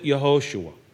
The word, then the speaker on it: Yehoshua: Jesus is the Greek transliteration of his actual Hebrew name, which was more like Joshua, or the way they probably pronounced it, Yehoshua.